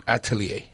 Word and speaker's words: Atelier: Is it L'Atelier or L'Atelier? – It's Atelier. – Atelier. – It means the workshop. – So you didn't even pronounce the L. – Right. – Just Atelier.